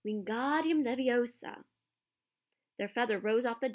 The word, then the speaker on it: Wingardium Leviosa: "Ingardium Leviosa," he shouted, waving his long arms like a windmill. "You're saying it wrong," Harry heard Hermione snap. "It's Wingardium Leviosa. Make the gar nice and long."